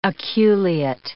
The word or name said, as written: aculeate